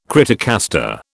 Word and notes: criticaster: British English

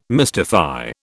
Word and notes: mystify: British English

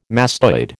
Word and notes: mastoid: American English

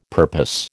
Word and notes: purpose: American English

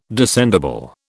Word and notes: descendible: British English

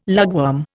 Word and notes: lugworm: Indian

